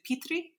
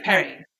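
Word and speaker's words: Peri: Is it Petri? – Perry.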